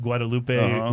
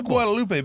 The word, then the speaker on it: Guadalupe: So it is Guadalupe, but if you're in Austin and you go down Guadalupe Street. – Yeah, that's right. – Don't pronounce it Guadalupe. They look at you weird.